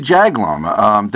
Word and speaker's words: Jaglom: And it seems to me that you work a lot with Henry Jaglom.